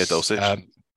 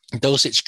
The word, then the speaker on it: Dulcich: Yeah, Dulcich. – Dulcich can't really block.